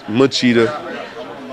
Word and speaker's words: Machida: Lachita is, did I say his name right? Lachita? Because he run all the time. Because he run all the time, Lachita. Machida.